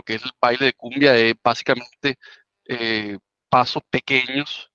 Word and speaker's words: Koombea: Como, como, tú decías, el nombre sale del, diccionario cumbia de la palabra es la pronunciación, la, forma como lo escribimos es la pronunciación fonética del baile, del baile cumbia,